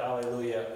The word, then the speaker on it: Hallelujah: So my wife knows that, and whenever we sing hymns and it's got hallelujah and I'm standing next to her, I'll always emphasize the H. Hallelujah. Because that's how the Greek word is spelled. It's not spelled without the H.